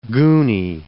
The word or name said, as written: goony